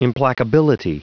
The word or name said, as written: implacability